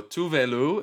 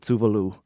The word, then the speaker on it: Tove Lo: We are talking about Tove Lo, if we're speaking in American, or Tuve Lu, if we're speaking in her native tongue of Swedish.